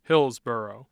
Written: Hillsboro

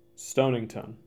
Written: Stonington

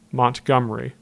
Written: Montgomery

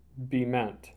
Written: Bement

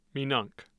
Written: minonk

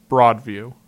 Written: Broadview

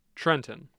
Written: Trenton

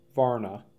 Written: Varna

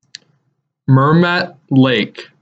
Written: Mermet Lake

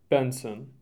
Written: Benson

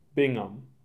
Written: Bingham

